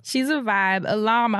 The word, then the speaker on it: Ilham: So there's an artist named, I hope I'm saying it right, Ilam. It's spelled I-L, is it just one L? I-L-H-A-M. So I think it's Ilam.